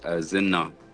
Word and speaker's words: Zina: Actually, it's a confusing spelling. It's Zina. – Zina. – So really, it should be Z-I-N-A, really.